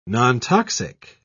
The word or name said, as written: nontoxic